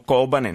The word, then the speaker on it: Kobane: Ho detto Kobane, non Kobane, come dicevamo fino a qualche giorno fa, perché abbiamo eh, scelto, visto che la città è curda, abitata da curdi, di adottare appunto la pronuncia curda al posto di quella turca.